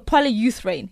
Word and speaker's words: polyurethane: But the the plastic is you know the kind of the, the, the polyurethane. – Is that yeah. the way to pronounce it? Yeah, yeah. Yes. Polyurethane.